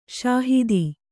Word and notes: ಷಹೀದ: ṣahīda

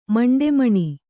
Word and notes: ಮಂಡೆಮಣಿ: kannada

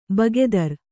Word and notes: ಭಾಗೇದಾರ: Kannada

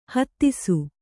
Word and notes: ಹತ್ತಿಸು: Kannada

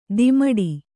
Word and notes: ದಿಮಡಿ: dimaḍi

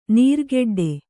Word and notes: ನೀರ್ಗೆಡ್ಡೆ: kannada

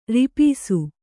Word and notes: ರಿಪೀಸು: ripīsu

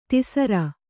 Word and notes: ದಾಸರ: dāsara; kannada

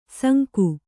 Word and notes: ಸಂಕ: kannada

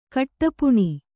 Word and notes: ಕಟ್ಟಪ್ಪುಣಿ: kaṭṭappuṇi

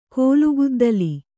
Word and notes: ಕೋಲುಗುದ್ದಲಿ: kōlu guddali

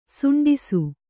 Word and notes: ಸಂಡಾಸ: saṇḍāsa; Kannada

